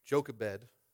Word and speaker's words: Jochebed: Jochebed.